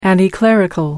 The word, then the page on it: anticlerical: American English